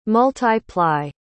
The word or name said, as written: multi-ply